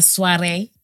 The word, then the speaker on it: soiree: Yeah, hey, guys, we pronounce it as soiree.